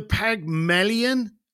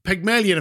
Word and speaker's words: Pygmalion: The Pygmalion effect. Have you heard of that? Mm-hmm. Right. Am I saying it wrong? – Yeah. Pygmalion effect.